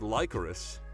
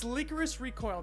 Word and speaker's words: Lycoris Recoil: Is that how you pronounce it? I thought it was Licorice. – It's Lycoris Recoil. They call it Lico or Liquor.